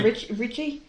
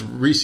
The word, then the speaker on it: Ricci: Well, it's R-I-C-C-I. Isn't that how she pronounces it? Because I've heard it both ways. I've heard media people say Christina Reese, and I've heard Richie before, too. I've heard both. – I've only ever heard Richie.